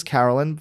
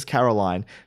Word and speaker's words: Carolyn: Let's talk about Princess Carolyn first. And I'm saying Princess Carolyn because even though half the people say Princess Caroline, she says Princess Carolyn. So I'm going to go with that.